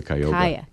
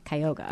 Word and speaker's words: Cuyahoga: Is it the Cuyahoga? – It's the Cuyahoga. – Cuyahoga. – It's the Cuyahoga, yeah.